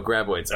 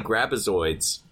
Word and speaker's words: Graboids: I just keep wanting to say Grabazoids. Someday. When I repop in my HD DVD of Tremors, I'll be able to remember it's Graboids, not Grabazoids.